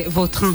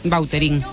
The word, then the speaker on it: Vautrin: Pero aquí decimos Bauterín. – Claro, sí, ¿no? Pues... – Es que si me pregunta exactamente cómo se pronuncia, pues así. Claro, pero sí. Yo ya soy Bauterín. – Ya. – Está españolizado total.